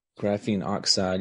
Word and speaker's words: graphene oxide: Now, is graphene, how do you say graphene oxide? – Graphene oxide.